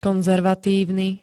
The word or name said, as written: konzervatívny